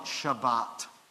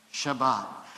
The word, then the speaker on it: Shabbat: We call it Shabbat. Say that with me, Shabbat. That's how it's said in Hebrew. And if you ever go to Israel, I hope you will, you should say it Shabbat.